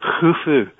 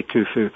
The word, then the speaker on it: Khufu: It's actually pronounced as in loch, so it's actually Khufu as opposed to Khufu. But most people just say Khufu.